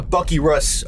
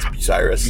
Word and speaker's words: Bucyrus: By the way, this is, welcome to Bucky Russ, Ohio. – It's called Bucky Russ? – That's how you pronounce it? – No, it's Bucyrus.